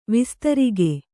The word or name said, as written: ವಿಸ್ತಾರಕ